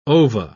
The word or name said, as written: ova